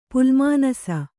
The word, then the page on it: ಪುಲ್ಮಾನಸ: kannada